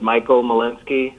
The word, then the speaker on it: Michael Molinsky: My name is Michael Malinsky. That's spelled M-I-C-H. A-E-L-M-O-L-I-N-S-K-Y, and I'm a parking code enforcement officer for the City of Portland.